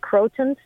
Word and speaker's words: croton: I believe it's pronounced croton, C-R-O-T-O-N. – Yep, croton, yep.